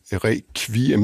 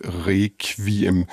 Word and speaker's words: requiem: Der siger man requiem med kv, ligesom på dansk, og der er mange andre lyde, der bliver udtalt anderledes.